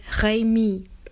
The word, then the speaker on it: Remy: 咪咪是一个小男孩的名字，原文是 Remy， R-E-M-Y， R 在法文里面发音是 H，一个打后舌音的 H，有点像 H 的音，不过要再难一点点哦，因为你后面舌头咕噜咕噜，要有点像鸽子这样咕噜咕噜叫一下。